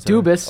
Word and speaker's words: Dubas: Jay wanted to point out that he met Kyle Dubois. – It's Dubas. – Okay. – It's wow. – Kyle Dubas.